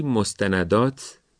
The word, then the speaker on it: مستندات: بعضن میبینیم که کم هم نمیبینیم، این دوتا واژه رو با تشدید تلفظ میکنن و در نوشته هم میبینیم تشدید رو میذارن. کجا؟ مستندات روی دال، ملحقات روی قاف. هیچ کدوم از این کلمه ها که هر دو عربی هستن تشدید ندارن.